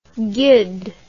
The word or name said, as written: guid